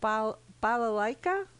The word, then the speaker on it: balalaika: balalaika. Did I pronounce that correctly? – You did.